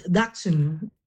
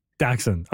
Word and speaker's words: Dachshund: Dachshund, Dachshund, how do we pronounce the breed? – according to the chairman, it's Dachshund. – Dachshund.